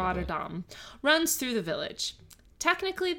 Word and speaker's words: Rotterdam: Rotterdam. – No, Rotterdam. – Rotterdam? – Dam rhymes with bomb. It is not dam as in dam.